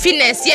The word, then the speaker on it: Fiennes: And in the film, the renowned chef Julian Slowick, who was played by Ralph, I never know how to say his name. Fiennes? – I just call it Fiennes. – Fiennes, yeah. Right, Ralph Fiennes.